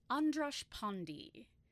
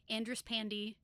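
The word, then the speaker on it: András Pándy: So according to the pronunciations that I looked at, it's Andras Pandy.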